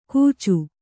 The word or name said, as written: ಗೂಚು